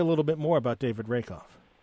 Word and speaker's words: Rakoff: And that's the late David Rakoff.